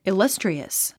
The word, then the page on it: illustrious: ilʌ’striəs; イラストリアス